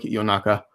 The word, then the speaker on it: Yonaka: Although the actual Japanese word is pronounced a little differently. It's more like Yonaka. It's their name. They get to decide how to pronounce it.